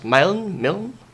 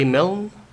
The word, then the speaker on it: Milne: Milne. A. Milne.